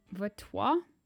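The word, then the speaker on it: Vautour: So the couples were Elaine and Anne Vatois. That's what McGee says is pronounced.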